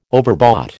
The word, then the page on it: overbought: American English